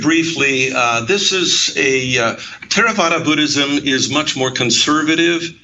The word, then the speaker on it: Theravada: You've mentioned uh, several times that we're talking about a particular kind of of Buddhism, uh, and I'll take a shot at pronouncing it Theravada, but that, I'm, I'm, sure I've got that wrong. Uh, you got uh, it right.